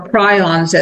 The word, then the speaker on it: prions: And by the way, that very well may include prions or prions, as it should be pronounced, which are misfolded proteins, which is what we're talking about.